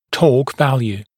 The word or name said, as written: torque value